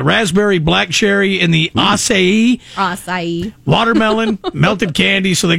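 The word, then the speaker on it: açaí: Is it a K or a Kai? I think it is a Kai, right? – No, the acai. – Acai, yeah. That's acai, how you say it.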